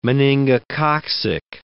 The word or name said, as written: meningococcic